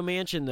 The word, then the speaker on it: Manchin: It's Manchin.